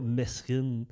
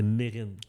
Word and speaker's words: Mesrine: mais je me rappelle plus ça vient de où, mais que c'était pas Mesrine. C'est Mérine.